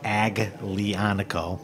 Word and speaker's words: Aglianico: Yes, but then also Alianico, because... – That is, tell people where Alianico is the grape. – Alianico is the grape. – It's pronounced Aglianico, L-I-A-N-C-O, and it's from where? – It's from Campania.